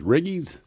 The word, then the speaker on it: Riggies: Oh, Riggies. Riggies? – Yeah, Riggies.